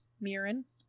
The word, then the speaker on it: Myrin: I look at Marin. Mirin. – Mm-hmm. – And I say, should spell more of my names. His name is spelled M-Y-R-I-N.